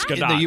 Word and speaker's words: Gadot: Gadot or Gadot? What is it? – It's Gadot. – You pronounce the T, yeah.